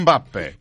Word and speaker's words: Mbappe: With his surname is spelt M. B-A-P-P-E. So you have to say as in the M.